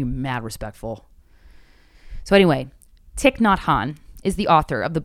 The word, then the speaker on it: Thich Nhat Hanh: Thich Nhat Hanh is how I'm going to say this is pronounced based off the average pronunciation on this video.